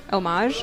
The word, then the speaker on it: homage: Homage? Homage? – I think they're actually both right. Yeah. – Vocabulary. – Yeah. – So, as long as you don't pronounce the H, I think.